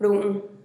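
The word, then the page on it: ru'un: Teotitlán del Valle Zapotec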